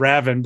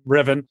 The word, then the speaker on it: Revan: Like Revan, I mispronounced his name and said Raven, but Revan.